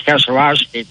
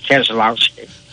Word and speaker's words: Keselowski: Well, it's like the proper pronunciation is Keselowski, but everybody, including Brad, says Keselowski.